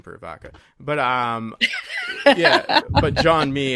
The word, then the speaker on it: Jean-Mi: Jean-Mi. – Is it Jean-Mi? I thought it was Jean-Mi. Oh, okay. Jean, like J-E-A-N, the French pronunciation, and then Mi, M-I.